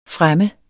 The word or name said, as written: fremme